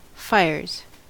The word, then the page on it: fires: American English